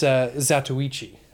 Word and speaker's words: Zatoichi: And I know how it's pronounced. It's Zatoichi.